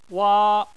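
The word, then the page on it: wa: wa1; tone 1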